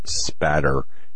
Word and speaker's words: spatter: It's blood spatter, no L.